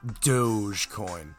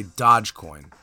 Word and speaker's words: Dogecoin: I know it's pronounced Dogecoin, but I like to sound like somebody who is uninformed and uneducated. So to dumb myself down to reach a broader audience, I'm going to say Dogecoin.